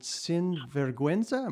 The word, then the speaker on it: Sin Vergüenza: I assume it was a Latin-influenced TV series called Sin Vergüenza. Am I pronouncing that right? – Yeah.